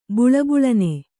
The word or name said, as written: ಬಳಬಳನೆ